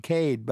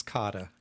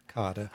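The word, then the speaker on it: Kade: You know, and that first little introduction I did in the beginning, I pronounced it the Max Cade Institute, and I looked up the pronunciation, and it said most Americans pronounce it Cade, but it's not. – That's correct. His name is pronounced Max Kada. Kada.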